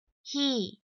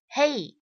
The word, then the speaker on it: he: OK，首先第一个男性他这个代词读作是 he he，而很多同学呢都会把这样的一个词读成是 hey。